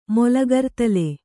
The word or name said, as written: ಮೊಲಗರ್ತಲೆ